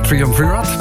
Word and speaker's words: Triumvirat: Waterfall, Triumvirat. Ik weet natuurlijk dat het uitspreek. Zeg je dat? – Ik denk Triumvirat.